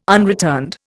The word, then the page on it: unreturned: Indian